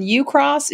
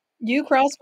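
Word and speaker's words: Ucross: He lives in U-Cross. Did I say that right, Craig? – You did. You sound like a native. – U-Cross, Wyoming, population 26.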